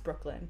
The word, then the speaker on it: Brookland: Well, if you're ever in DC again, Brooklyn, it's pronounced Brooklyn, but it's spelled like Brookland, is like a great scene for the arts.